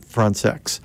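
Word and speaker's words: Françaix: And that's the way he pronounced his last name. He thought Americans were actually kind of funny about their qualms about that pronunciation, but he would pronounce it Frantzex.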